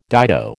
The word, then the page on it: dido: American English